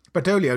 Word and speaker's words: Badoglio: Badoglio, you don't pronounce the G, like in Bolognese.